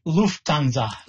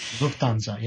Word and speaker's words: Lufthansa: How do you say that Air Canada and Lufthansa? Every time I see it in writing, I struggle with the word. – Lufthansa. Lufthansa, yes. The German version of the German airline, Lufthansa.